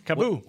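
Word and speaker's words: KAABOO: Kaboo? – Kaboo.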